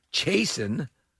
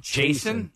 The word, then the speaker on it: Chasen: Connor J, Connor S, Chasen. – Wait, Jason? – Yeah. – Spell that. – Not Jason. C-H-A-S-E-N.